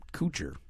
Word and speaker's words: Kutcher: How do you pronounce his last name? Kutcher.